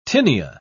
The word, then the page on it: tinea: British English